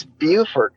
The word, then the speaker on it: Beaufort: It's Beaufort in South Carolina. – Yeah. – In North Carolina, it's spelled the same and they call it Beaufort.